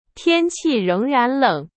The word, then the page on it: 仍然: Chinese